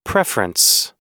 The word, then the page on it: preference: American English